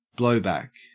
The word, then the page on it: blowback: Australian English